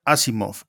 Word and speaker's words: Asimov: Asimov.